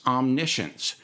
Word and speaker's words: omniscience: The right pronunciation, although it's spelled omniscience, is omniscience.